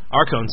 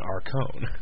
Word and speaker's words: Arcone: He keeps insisting that his name is Arcon. – Yeah, I'm just not going to pronounce it that way because it's obviously written Arcone. – Mm-hmm. – If it was Arkohen, it would be Arcoin. – True. Because O-H by itself is O.